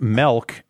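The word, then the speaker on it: mælk: Yeah, it's like, which is pronounced melk, is milk in Danish.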